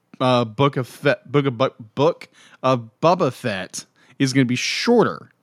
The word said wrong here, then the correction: Boba Fett: That's called redneck Boba Fett is what I'm getting.